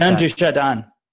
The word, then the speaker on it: Jean Dujardin: It's by Quentin Dupieux, and it is starring Juan Jourdain. I'm sorry if I mispronounced Jean Dujardin.